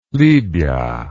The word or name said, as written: libia